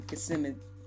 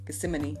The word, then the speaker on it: Gethsemane: Uh, one of them is the prayer in uh, Gethsemane. I knew I was going to say it wrong. Gethsemane.